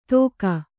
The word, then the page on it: ಧೋಕಾ: dhōka